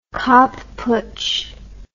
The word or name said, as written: Kapp Putsch